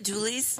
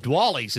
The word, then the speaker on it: Duallys: Isn't it Dooley's? – It is. – Okay. – But she didn't know that. – Oh. – And Jeff Smith, who did the story earlier in there, said it right. – Right. – And then when she did it like 30 minutes later, she said Dwally's. And I'm like, that's Oops. Funny. Maybe we should call it that because it's spelled what? D-U-A-L-L-Y-S? – Oh, Dooley's?